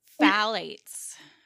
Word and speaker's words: phthalates: Phthalates. Phthalates. Oh, I know. Phthalates. It's one of those words that's not spelled how it's pronounced.